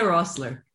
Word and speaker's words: Roessler: Okay, let's see if I can not butcher your last name, because I'm going to say Roessler, but that's probably wrong. – No, that's what I did. That's what I do. – I noticed. Yeah. No. Correct me. – It's Lina Roessler. – Roessler. Very good. I tried to do the American pronunciation, not the Canadian.